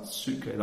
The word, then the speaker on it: psuche: And then there's something called the psuche, spelled P-S-U-C-H-E. It's pronounced psuche, and that's our internal life.